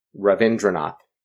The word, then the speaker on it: Ravindranath: Ravindranath.